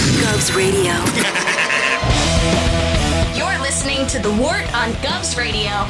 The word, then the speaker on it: Wort: It is The Wort. Not wart, not fort, not short.